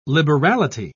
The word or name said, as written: liberality